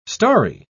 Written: starry

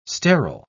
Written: sterile

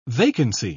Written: vacancy

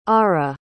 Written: Arra

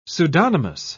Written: pseudonymous

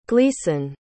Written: gleason

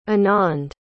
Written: anand

